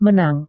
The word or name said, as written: menang